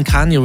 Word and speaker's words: Kanye: Nach der Trennung von Kanye West hat... Habe ich es richtig ausgesprochen? – Ja.